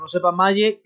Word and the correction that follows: Maye: creo que lo estoy pronunciando mal, todo se ha dicho. – May, May. May.